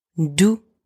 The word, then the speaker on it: doux: Doux. Soft or sweet. Doux. Same, don't pronounce the X at the end.